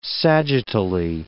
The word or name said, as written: sagittally